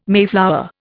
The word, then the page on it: mayflower: Indian